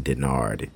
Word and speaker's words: Dennard: Joshua HB Denard. I almost spelled my full name. But and a way to spell that is just Joshua Happy Birthday, like HB Denard, D-E-N-N-A-R-D.